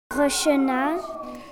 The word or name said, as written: Rachena